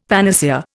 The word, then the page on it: panacea: Indian